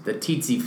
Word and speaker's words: tsetse: It's like the pterodactyl. The T is silent. – You probably talk to three different ID docs, and they probably say, no, pronounce that T along with it. I usually say tsetse fly.